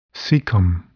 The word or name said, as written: cecum